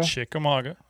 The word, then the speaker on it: Chickamauga: It said that right, didn't I, guys? Chickamauga? – Chickamauga. That's correct.